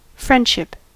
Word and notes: friendship: /ˈfɹɛndʃɪp/; English

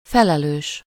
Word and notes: chief: /tʃiːf/; American English; [tʃiːf]

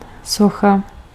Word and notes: beeld: /beːlt/

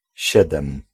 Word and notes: siedem: /ˈɕɛd̪ɛm/

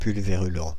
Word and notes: pulvérulent: [pyl.ve.ʁy.lɑ̃]

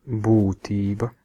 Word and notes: essence: /ˈɛs.əns/; [ˈɛs.əns]

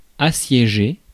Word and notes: assiéger: [a.sje.ʒe]; France

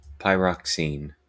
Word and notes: pyroxene: en-us: IPA: /paɪˈɹɑk.sin/; uk: IPA: /paɪˈɹɒk.siːn/

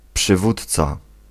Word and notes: przywódca: [pʂɨˈvut͡s̪t͡s̪a]

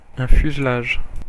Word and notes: fuselage: IPA: /fyz.laʒ/; French

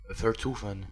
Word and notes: demeurer: France; /də.mœ.ʁe/

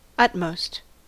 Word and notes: utmost: /ˈʌtmoʊst/; American English

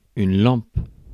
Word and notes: lampe: France; /lɑ̃p/

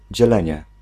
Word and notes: division: /di.vi.zjɔ̃/

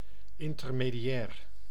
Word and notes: intermédiaire: France; IPA: [ɛ̃.tɛʁ.me.djɛʁ]

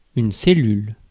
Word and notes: cellule: /se.lyl/; France

